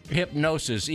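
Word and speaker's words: Hipgnosis: I think it's pronounced hypnosis, even though it's spelled weird.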